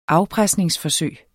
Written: afpresningsforsøg

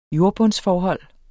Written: jordbundsforhold

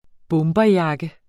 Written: bomberjakke

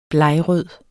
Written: blegrød

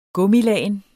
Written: gummilagen

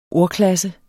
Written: ordklasse

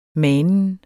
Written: manen

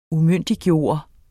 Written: UMYNDIGGJORTE

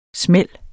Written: smæld

